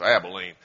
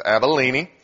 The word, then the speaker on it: Abilene: And that's actually the correct pronunciation. It's not like Texas, where it's Abilene. It's Abilene. And collectively, these regions represent pretty much all of the area of influence around the area where Jesus lived.